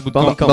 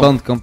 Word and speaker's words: Bandcamp: Alors, tout à l'heure vous disiez Bootclamp, c'est ça Bandcamp. Bandcamp, ouais.